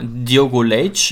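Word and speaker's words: Diogo Leite: Aber klar, Diogo Leitch, ich glaube, so wird er wirklich ausgesprochen, hatte wirklich einen rabenschwarzen Tag.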